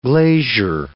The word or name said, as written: glazier